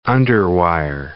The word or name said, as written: underwire